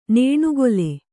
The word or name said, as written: ನೇಣುಗೊಲೆ